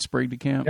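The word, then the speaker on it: Sprague de Camp: Am I saying that right, Sprague DeCamp? – Yes. Okay. Sprague.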